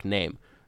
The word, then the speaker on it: Nehm: Or no, it is Eric Nehm. I'm sorry. Not Eric Nehm. We've been saying Nehm. It's Eric Nehm. That's how it's pronounced in his Twitter bio.